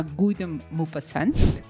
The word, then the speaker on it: Guy de Maupassant: que se llama Guy de Maupassant. – esa es la buena pronunciación.